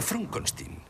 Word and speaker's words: Frankenstein: No, es pronuncia Frankenstein.